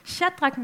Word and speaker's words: Shadrach: der hedder Shadrach, Meshach og Abednego. Det er helt rigtigt udtalt, det kan jeg lige så godt bare sige med det samme.